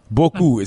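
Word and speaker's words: beaucoup: There are two ways to say that, but boku is how you say that word en français.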